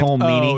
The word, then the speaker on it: Colm Meaney: Colm Meaney.